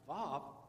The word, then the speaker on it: Vav: So this second letter that you see is pronounced Vav, and it is a picture of a nail.